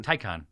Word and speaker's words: Taycan: Taycan.